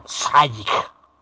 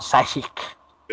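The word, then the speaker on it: Šejić: Según le comentó la Spy que mantiene correspondencia con él seguido, la pronunciación es algo así como Sajic. No, no. Sí, es una A acentuada y la C del final la tienes que arrastrar como una J muy larga.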